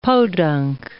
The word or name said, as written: Podunk